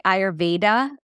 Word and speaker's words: Ayurveda: In the West, we say Ayurveda because we're not used to saying the sound like we do in India. It's Ayurveda, like you're saying the.